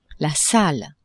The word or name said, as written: salle